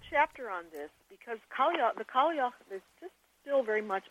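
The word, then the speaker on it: Cailleach: Well, let's talk about the old woman goddesses who, I may mispronounce this, but I'll do it my best, was called Kalyak. – Kalya, yeah, the Kalya. – Oh, Kalya, okay.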